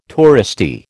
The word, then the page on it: touristy: American English